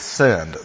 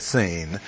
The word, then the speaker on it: Sin: This has nothing to do with sin. They sinned at Sin, but the word is pronounced Sin.